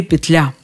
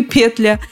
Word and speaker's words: петля: Ударение либо на первом слоге петля, либо на втором петля. Что ж, попробуем разобраться.